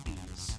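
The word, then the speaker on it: Ys: and they do pronounce it Yves. So if you're wondering out there, that is the correct pronunciation of the title of the game.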